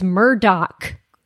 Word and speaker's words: Murdaugh: And also, other than all of the murders, the weirdest thing about the family is that the name is spelled Murdaugh. Should be Murdaugh, but it's Murdoch.